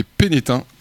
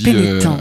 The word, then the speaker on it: Pénestin: Et Pénétin, comme on dit. – Pénétin, on ne prononce pas le S quand on connaît le patelin.